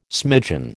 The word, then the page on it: smidgen: American English